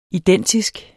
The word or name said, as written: identisk